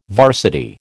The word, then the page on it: varsity: American English